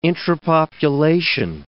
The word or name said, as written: intrapopulation